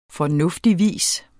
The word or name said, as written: fornuftigvis